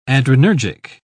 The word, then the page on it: adrenergic: British English